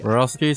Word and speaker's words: Borowski: Borowski sounds pretty much how you would pronounce it.